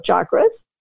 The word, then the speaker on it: chakra: Now, first of all, I want to say I've obviously mispronounced it. I've westernized it in with the sh sound as opposed to the ch. So I apologize for that, being the ugly American here. But chakra, I will pronounce it correctly.